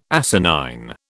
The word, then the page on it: asinine: British English